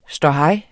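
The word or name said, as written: ståhej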